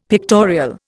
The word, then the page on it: pictorial: Indian